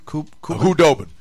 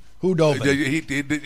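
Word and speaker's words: Khudobin: You could get caught in fooled with a guy like, I might not be able to pronounce his name right, Antoine Kubanon? – Hudobin. – Hudobin.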